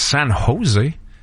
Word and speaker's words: San Jose: Puis un ou deux, au lieu de dire San Jose, qui est la prononciation parfaite, un ou deux aurait dit San Jose.